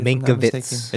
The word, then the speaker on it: Mankiewicz: It is about Herman Mankiewicz. I believe I'm saying that right. – Mankiewicz. – Anyway. – He's Jewish. Mankiewicz.